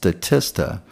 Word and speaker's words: Statista: I may have said it wrong earlier, Statistica, but I believe the website is pronounced Statista.